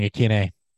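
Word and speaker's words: Ikhine: I just see Nick Westbrook Ikeen, and that's the one I can actually like. – Oh, we've been saying it wrong. Ikeene.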